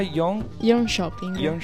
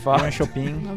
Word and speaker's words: Jönköping: Abbiamo detto, ricordami la pronuncia, Jönköping. – Io l'ho detto alla cinese. – Jönköping. – Lo pronunciare normale, tranquillo. – Sino a Shanghai. – Alla svedese o alla cinese. Scritto Jönköping con la K, però. – E le due umlaut, detto alla tedesca, sulle due O.